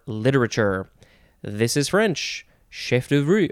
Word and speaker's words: chef d'oeuvre: Okay, next we have, could pronounce this a couple ways, chef d'oeuvre, or chef d'oeuvre, that sounds a little bit more American. Chef d'oeuvre seems more authentically French.